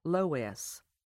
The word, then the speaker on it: loess: We call this wind-blown soil loess, pronounced Loess.